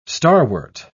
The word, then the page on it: starwort: British English